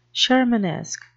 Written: shermanesque

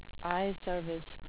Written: eyeservice

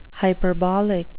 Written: hyperbolic

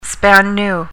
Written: span-new

